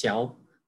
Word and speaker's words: Chow: Say it in Chinese. In Chinese, it's Chow. But a lot of people just say Chow just because they can't pronounce it.